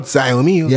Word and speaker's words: Xiaomi: That's how you pronounce this, not Xiaomi.